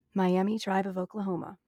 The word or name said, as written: Miami